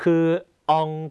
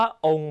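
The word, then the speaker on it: ông: ององกับองนะฮะตัวนี้คนไทยออกเสียงผิดเยอะนะครับคำว่าองภาษาเวียดนามแปลว่าปู่นะฮะคนไทยชอบออกเสียงว่าององนะฮะจริงๆไม่ใช่จริงๆต้องออกเสียงว่าองนะครับ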